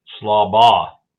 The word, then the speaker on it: Schlabach: Schlabach.